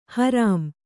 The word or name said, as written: ಹರಾಂ